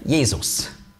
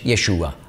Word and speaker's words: Jesus: Das ist die griechische Aussprache von Hebräisch Jeshua. Und bedeutet genau das Gleiche. Und es gibt Leute, die denken, sie seien noch ein bisschen besser und biblischer, wenn sie sagen Jeshua. Kein bisschen. Ist einfach die andere Aussprache.